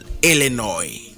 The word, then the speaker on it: Illinois: Illinois the word is Illinois sn is silent